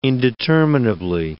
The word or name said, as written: indeterminably